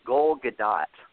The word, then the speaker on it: Gadot: By the way, fellas, it's Gal Gadot, like seagull.